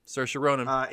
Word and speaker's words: Saoirse Ronan: best actress in a musical or comedy went to my girl, Saoirse Ronan. Is that how you say it? – Yep, Saoirse Ronan. – In Lady? That's Saoirse? – Yeah. – Saoirse as in inertia.